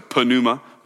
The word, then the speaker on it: pneuma: We have silent P's in English. They don't have silent P's in Greek. So pneuma is what we want to say.